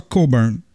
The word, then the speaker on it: Cockburn: Bruce Cockburn. Mais je pense qu'il prononce ça Coburn.